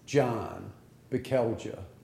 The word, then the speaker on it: John Bekelja: John Bekelja. – And I'm sorry, could you spell that? – B-E-K-E-L-J-A.